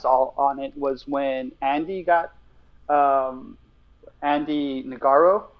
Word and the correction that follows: Ngo: it's pronounced Andy Ngo, just N O. Uh, Andy Ngo.